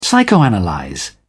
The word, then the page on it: psychoanalyse: British English